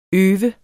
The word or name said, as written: øv